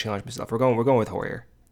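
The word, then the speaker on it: Heuer: It is spelled H-E-U-E-R. I believe on Marquee, Boog has said Hoyer. So that's what I'm going with. If I am butchering that, I apologize. Brendan is subsequently also going to butcher it because we agreed on how to say it together. So Hewer. – Yeah, I don't think it's Hewer, but we're going with Hoyer.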